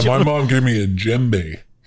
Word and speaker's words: djembe: I'd like to put djembe. – Djembe. Yeah. – Djembe, you spell that D-J-E-M-B-E.